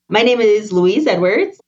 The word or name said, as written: Louise Edwards